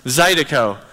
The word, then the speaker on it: zydeco: Zydeco.